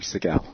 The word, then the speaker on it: Segal: Well, Seagal. Looking Seagal. At it, you might say Segali, just from the way it's spelled.